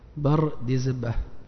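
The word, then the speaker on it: بردزبه: اضبطوا هذا، جد البخاري هذا، حتى تنطقوا يعني نطقا صحيحا، بفتح الباء وبسكون الراء وكسر الدال وسكون الزاي وفتح الباء وسكون الهاء أيضا. بر ديزبة.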